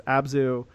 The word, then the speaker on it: Abzu: Abzu, I'm saying it right, correct? – Yeah, yeah, absolutely.